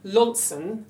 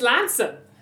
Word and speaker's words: Launceston: I had two girls in my class and I said Launson, meaning Launceston. And they said, oh no, it's not. No, it's Lanson.